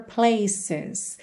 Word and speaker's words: places: Places, gente, olha que maravilha, uma letra E que é pronunciada, tá ali na última sílaba entre uma consoante, que é a letra C, e o S, e ela é pronunciada.